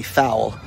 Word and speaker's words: Fowl: And there is a company called Party Fowl, and uh, it's spelled F O W L, like foul, like bird.